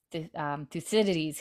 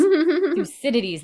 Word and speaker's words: Thucydides: And he's like, "That was Thucydides who said that, not me." I butchered Thucydides. There we go. That was Thucydides that said that, not me.